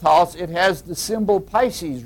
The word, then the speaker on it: Pisces: I'd say Pisces, which is the proper pronunciation, but most people cringe.